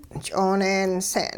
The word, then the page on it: ch'ooneense'n: hupa